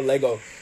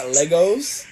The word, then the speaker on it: Lego: It's not Legos. It like, cause I was watching Fact Fiend, and it's like, it's not Legos. It's Lego. I'm like, what? – So yeah, it is Lego.